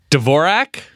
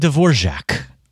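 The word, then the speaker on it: Dvořák: Dvorak? – That's how I pronounce it. I'm sure I'm wrong. – Antonin Dvorak is rolling over in his grave because it's Antonin Dvorak. – Dvorak.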